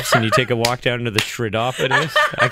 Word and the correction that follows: chiropodist: It's actually a chiropodist, kind of like chiropractor.